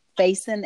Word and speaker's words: Faison: Yeah, my station name is Why Struggle, but the name is Barbara, and that's B-A-R-B-A-R-A. The last name is spelled Faison, F-A-I-S-O-N.